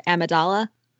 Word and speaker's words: Amidala: Plus I think I usually hear people pronounce it Amidala with a different stress on the syllables, right, which would change it completely.